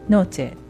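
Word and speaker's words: noche: で、同様にノーチェだったらノとチェでやっぱり、母音で終わっているので後ろから 2番目のノの方が強く発音されてノーチェというふうになります。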